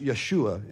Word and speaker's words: Yeshua: It's a Hebrew name pronounced Yeshua in Hebrew.